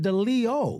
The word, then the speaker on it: Deleo: Yes, so Canon, it's Deleo, as in of the lion. – Well, you just said Deleo.